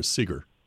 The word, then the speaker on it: Segar: Or Segar? – That's how Segar. I think he always drew a cigar next to his name, so I figure that's how he wanted it pronounced.